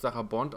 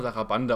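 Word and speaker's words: Sarabande: Wenn wir auch gerade, du hast gerade eben gesagt, dass im Barock eben auch viel, ich kann, ich, ich du hast Sarah Bond ausgesprochen? – Ja. – Ich habe immer Sarah Bande ausgesprochen. Ich glaube, das war falsch.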